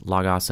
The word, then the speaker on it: Logos: and I did pronounce Logos and Logos differently because they are interchangeable. Both are correct.